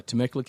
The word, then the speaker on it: Temecula: You live in, how do you say it, Temecula? – Yeah, Temecula, California.